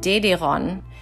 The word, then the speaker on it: Dederon: And Dederon, if it's spelled Dedron, it's supposed to, the way it's pronounced is like DDR, so it gives it a specific type of branding that is unique to the GDR or the DDR.